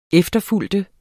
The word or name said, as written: efterfølger